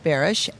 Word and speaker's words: Barish: B-A-R-I-S-H. – Okay. – Bearish.